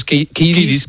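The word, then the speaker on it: Kiedis: Y ¿cómo pronuncia? Es Kiddis.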